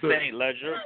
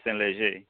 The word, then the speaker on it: Saint Leger: Nah, it's Saint Leger, but it's the French pronunciation is Saint Leger.